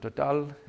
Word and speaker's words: Total: My last job was the corporate world, was Total, you know, the French petroleum company. They pronounce it Total.